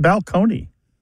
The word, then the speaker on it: balcony: My favorite change that I love to talk about when Martha and I go and do live events on the road is the word balcony, you know, like this place on a stage that you can look down upon the seats, used to be pronounced balcony.